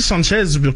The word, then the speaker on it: Sanchez: Mais Alexis Sanchez, comme ça, sans la prononciation du S, ouais. – C'est généralement ce que j'ai entendu. Maintenant, est-ce que les Français, on est la référence pour la prononciation des noms étrangers?